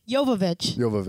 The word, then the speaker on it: Jovovich: Jovovich. There's no L. I don't know why we both added an L there.